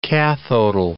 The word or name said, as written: cathodal